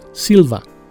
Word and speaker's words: Silver: Adoptó el apellido Silver por razones fonéticas, ya que Silver en la jerga callejera se pronuncia Silva. Por coincidencia, su verdadero apellido.